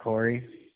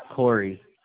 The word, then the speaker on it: Corey: Scott Corey. – Sir? Hello? – Hello? – That first and last name, sir? – Scott Corey. – Can you spell that first name? – S-C-O-T-T. – S-C-O-T-T. – And Corey, C-O-R-E-Y.